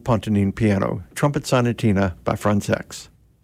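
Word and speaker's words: Françaix: And that's the way he pronounced his last name. He thought Americans were actually kind of funny about their qualms about that pronunciation, but he would pronounce it Frantzex.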